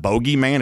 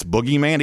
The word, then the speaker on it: boogeyman: So, even though the, the reporter mispronounced it, it's not bogeyman, it's boogeyman,